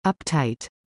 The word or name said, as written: Uptight